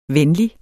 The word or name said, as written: venlig